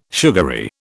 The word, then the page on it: sugary: British English